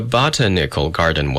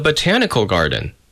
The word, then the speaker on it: botanical: Failing to locate the place, I asked the janitor where the botanical garden was with the accent on the first syllable. What? He said. I repeated. Oh, you mean the botanical garden, he responded after a moment, enlightened.